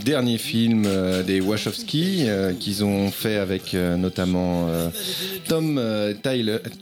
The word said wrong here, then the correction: Tykwer: Alors, je le prononce très très mal. Tykwer. Moi, je suis nul. C'était Y-K-W-E-R.